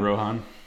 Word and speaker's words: Rohan: I think it's Rohan, right? – Yeah. – The Riders of Rohan. Rohan.